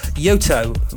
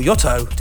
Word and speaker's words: Yotto: Next up is something new from another Sunset's favourite, Finnish producer Yotto, or Yotto, depending on how you want to pronounce it.